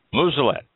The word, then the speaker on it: Muselet: Muselet. M-U-S-E-L-E-T. Muselet. Everybody's seen one. Everybody's probably played with one. Everybody probably has cursed one. But not Muselet. The T is silent. It's French.